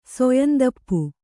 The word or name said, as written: ಸೊಯಂದಪ್ಪು